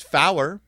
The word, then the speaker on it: Fower: Oh, in NATO and ICAO radio telephony, 4 is pronounced Fower. To avoid misunderstanding it with other words, like F-O-R, or F-O-R-E, if you're talking about golf, I guessed.